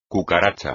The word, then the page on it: cucaracha: espagnol